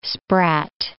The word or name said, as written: sprat